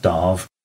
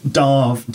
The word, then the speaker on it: Damh: So although it's pronounced Darv, I am Dave the Bard, and I just spelled Dave with an M-H at the end. So it's superb. – Actually, the weird thing is Darv, D-A-M-H, also means ox.